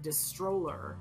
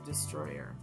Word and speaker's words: Distroller: And the company is Destroyer, but it's spelled D-I-S-T-R-O-L-L-E-R. So it sounds like Destroller, but it's they pronounce it Destroyer.